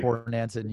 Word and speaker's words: Bornancin: Bornancin, you got it.